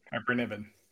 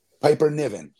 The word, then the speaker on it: Piper Niven: Piper Niven. – Piper Niven.